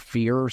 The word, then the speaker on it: FHIR: or like the FEAR system. – Oh, good. – Yeah. F-H-I-R, but pronounced fear.